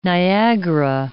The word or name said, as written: Niagara